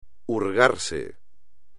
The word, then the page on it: hurgarse: espagnol